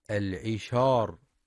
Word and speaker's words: العشار: وفي كلمة العشار ينتبه القارئ لترقيق الشين فلا يقرأ العشار بالتفخيم. وفي